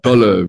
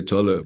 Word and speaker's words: Tolle: Tolle, I'm not sure how you pronounce it, Tolle or Tolle? – Tolle. We say in German, we say Tolle, but no, Eckhart Tolle.